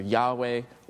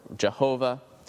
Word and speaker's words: Yahweh: The singer claims the Lord, in capital letters, stands for God's personal name, pronounced either Yahweh or Jehovah.